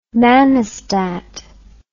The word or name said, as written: manostat